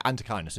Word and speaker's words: Antechinus: Or Antechinus. Is that how it's pronounced? – I think I might now suddenly be zeroing in on what we're on about. – Antechinus or... Yeah, Antechinus is what it's called.